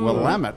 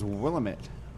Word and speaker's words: Willamette: Willamette. I always thought that was Willamette. – Yes. – It's pronounced Willamette. Okay, Willamette. We've learned something yep.